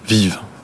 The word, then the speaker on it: VYV: on le prononce comme ça, Vive vive. Absolument. Moi, je dis VYV, je crois que c'est pas des initiales Non, ce c'est pas des initiales du tout. Donc, c'est l'appellation vive.